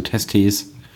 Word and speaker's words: Testes: Nicht so eine krasse Betonung auf dem zweiten E, eher so eine kurze Betonung auf dem zweiten E. – Ja. – Ablatio Testes.